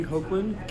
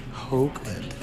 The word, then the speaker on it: Hoagland: It's H-O-A-G-L-A-N-D, Hoagland. Kevin, normal spelling. – Hoagland, H-O-A-N-G-L-A-N-D, Hoagland.